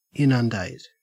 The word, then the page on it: inundate: Australian English